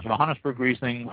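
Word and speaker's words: Johannisberg: This is Johannesburg Riesling, or Johannesburg Riesling, the J's not pronounced if you want to do it properly.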